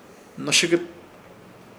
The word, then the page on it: Hnoshɨkɨt: Ishir; Chamacoco